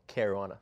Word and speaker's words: Caruana: You battled one of the best chess players in the world this past summer, Fabiano Caruana. – Caruana. – Thank you very much. – Dude, don't mispronounce my boy's name like that.